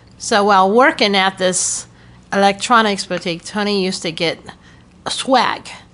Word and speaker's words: Electronics Boutique: And I didn't, like I said, I didn't grow up in this area, but Tony worked at an electronics boutique.